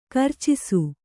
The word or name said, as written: ಕರ್ಚಿಸು